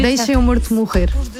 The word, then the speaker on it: Deixe-me Morto Morrer: There is a track called Deixe-me Morto Morrer. Mm-hmm. Am I pronouncing that? Am I butchering that pronunciation? – In Portuguese, it's very hard to pronounce Portuguese, but it's almost Portuguese.